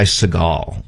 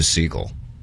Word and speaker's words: Segall: There are some people that say Ty Segall as opposed to Segal. – I think it's Segal, right? – That's always how I've said it.